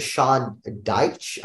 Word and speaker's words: Sean Dyche: And, besides players, we have, Lampard, the manager of Everton, and Sean Dyche, I believe is how you pronounce the name, the manager of Ajax gets sacked, based on bad form.